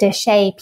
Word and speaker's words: Desha: And I'm on Instagram at Desha Peacock. And it's spelled D-E-S-H-A and then Peacock like the bird.